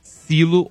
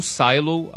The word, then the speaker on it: Silo: Faltam dois episódios pra eu terminar Silo ou Silo, a pronúncia você escolhe, que é a série do Apple TV.